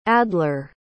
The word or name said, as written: adler